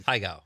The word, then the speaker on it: Pai Gow: Pai Gow? Is that how it is? – Pai Gow. Yeah.